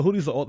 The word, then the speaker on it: Houthis: Yeah, it's the Houthis.